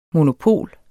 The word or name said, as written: monopol